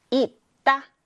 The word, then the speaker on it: 읽다: 읽다. 역시 읽다도 뒤에 있는 자음인 기역이 소리가 납니다. 읽다. 읽다에서 쌍시옷은 이렇게 디귿의 소리가 나요. 그래서 읽다라고 읽어줘야 합니다.